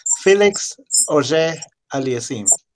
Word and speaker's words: Felix Auger Aliassime: The way you pronounce his name is Felix Auger Aliassime. Felix Auger Aliassime. That's how he says okay.